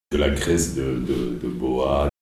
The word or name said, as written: graisse de boa